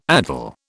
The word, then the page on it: addle: British English